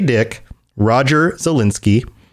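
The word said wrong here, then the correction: Zelazny: Roger Zelezny, right? That's how you pronounce it. – I think it's Zelezny. I've always said Zelezny.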